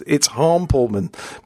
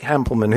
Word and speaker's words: Hampleman: I got really confused as to how to say his name because he sent me the message and he said, Hample, like you pronounce sample. Of course, Americans say sample and we say sample. So I think, oh no, it's Hampelman. But apparently it's not. It's Blake Hampelman, who's a teacher in Illinois, who I think has come up with a really interesting selection.